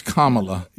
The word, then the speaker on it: Kamala: It's Kamala. – Yeah. – Yeah. Once I heard someone said, you know, the punctuation mark, comma.